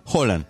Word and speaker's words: Haaland: ¿Lo escuchaste cómo se pronuncia? Haaland.